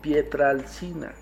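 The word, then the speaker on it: Pietrelcina: Padre Pío de Pietralichner perdón, va de nuevo, Padre Pío de Pietralcina.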